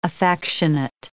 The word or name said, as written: affectionate